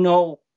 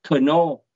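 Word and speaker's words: Knorr: Ở Việt Nam mình thì hay hay đọc là Knô, nhưng mà trên thực tế là cái chữ K á, là nó sai, là người ta không có đọc cái chữ K mà người ta đọc là thương hiệu Nô.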